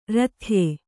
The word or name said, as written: ರಥ್ಯ